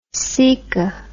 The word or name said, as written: sica